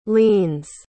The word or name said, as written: lenes